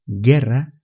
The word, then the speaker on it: guerra: Guerra se escribe G U E R R A. La U es muda, no se pronuncia. Guerra.